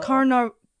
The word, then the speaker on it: Carnarvon: He sent a telegram to his chief financier of the expedition, George Herbert, 5th Lord of Carnarvon. – Well. – Carnarvon. I said it right.